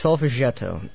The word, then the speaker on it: Solfeggietto: Solfeggietto? – That is pronounced Solfeggietto.